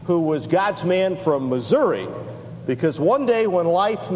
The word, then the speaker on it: Missouri: And whenever we see a Missouri, that's how they say it there, it's Missouri, not Missouri.